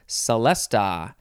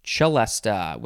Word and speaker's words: celesta: Next is celesta, or you could say celesta with a ch sound, or you could say celeste, spelled with an e at the end instead of an a. Celeste, celesta, celesta, celeste, all of those.